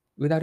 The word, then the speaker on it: OODA: u d a ループは英語で OODA と書いて u d a ループと読みます。